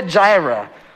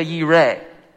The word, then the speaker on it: Jireh: In Hebrew, it is the name Jehovah Jireh, or sometimes, especially in more scholarly circles, because it really is closer to the Hebrew pronunciation, it would be pronounced Jehovah Yireh. We tend to make it more English because the beginning of each letter we spell with a J because of the Hebrew alphabet.